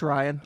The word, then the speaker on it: Rian: I think it's just Ryan Johnson. I've often overthought it, but I think it's just Ryan spelled weird.